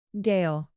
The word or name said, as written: gale